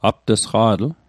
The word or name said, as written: apt'sqa'tl